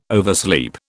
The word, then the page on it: oversleep: British English